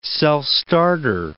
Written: self-starter

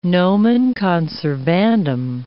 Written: nomen conservandum

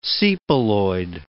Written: sepaloid

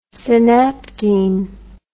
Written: synaptene

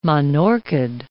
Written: monorchid

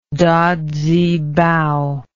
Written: dazibao

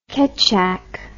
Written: kecak

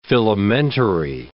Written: filamentary

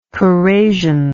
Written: corrasive

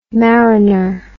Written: marriner

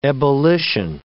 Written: ebullition

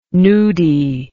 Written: nudie

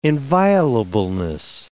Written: inviolableness

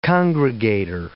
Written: congregator

